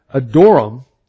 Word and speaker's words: Adoram: Then King Rehoboam sent Adoram. And that's, James, that's the way it's supposed to be pronounced according to the book we've got, you know. Adoram, that sounds like, you know, I kind of adored, talking about Adoram.